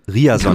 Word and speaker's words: Ryerson: Google Translate und gibst auf Norwegisch Riason genau.